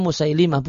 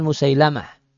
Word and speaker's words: Musailimah: Perlu diketahui bahasanya, cara bacanya Musailimah, bukan Musailamah. Namun Musailimah Al-Kadzab.